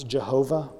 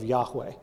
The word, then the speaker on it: Yahweh: Have you ever heard the word Yahweh before? Or sometimes it's Jehovah, which is actually a bad pronunciation of Yahweh. It's a German pronunciation. There is no J sound in Hebrew.